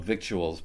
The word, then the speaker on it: victuals: It's spelled victuals, but it's supposed to be pronounced victuals.